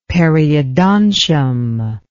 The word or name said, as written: periodontium